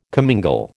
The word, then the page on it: commingle: American English